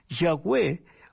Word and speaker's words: Yahweh: En hebreo se describe con Y, H, W y H sin vocales. Cuando se agregan las vocales se pronuncia Yahweh, que en español sería Jehová.